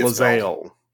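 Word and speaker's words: Lae'zel: and people really like either Karlach or I can't say her name, Lizzalila. – I have to see Lizelle. – Lizelle.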